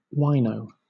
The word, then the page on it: wino: British English